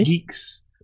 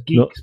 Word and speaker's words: Guix: Geeks. That was Geeks, by the way. – G-U-I-X. – Oh, Geeks.